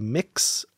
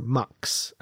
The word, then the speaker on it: Mx: Apparently there are a couple pronunciations. It can be Mix or Mux, apparently, for that. Mix T'Lir is how I would personally say that.